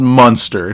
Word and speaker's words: Munster: It's called Munster. It's spelled like Eunster.